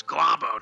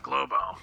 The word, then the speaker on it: Globo: I'm pretty sure it's Globo, not Globo.